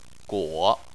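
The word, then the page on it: guo: tone 3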